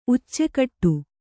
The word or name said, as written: ಉಚ್ಚೆಕಟ್ಟು